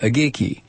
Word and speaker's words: Aguigui: He agreed to testify against Private Isaac Agiki, A-G-U-I-G-U-I, you pronounce it, Agiki, identified by prosecutors as the militia's founder and leader.